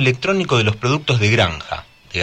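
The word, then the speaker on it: Granxa: Granja Familiar es granja así con X, ¿no? Se pronuncia granja porque es gallego.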